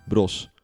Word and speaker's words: Broz: Jozef Bro Brois, B-R-O-Z. – Zo, spreek je dat zo? Ik weet, ik ben er altijd zo slecht in. Bros.